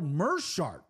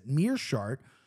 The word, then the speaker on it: Meerschaert: All right, next up, GM3, Gerald Meerschaert, which was the pronunciation of the week on Anik and Florian. I said Meerschaert because of the two E's. I've heard his name being pronounced a billion times, and I still fucked it up.